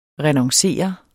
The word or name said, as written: renoncere